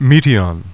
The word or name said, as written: METION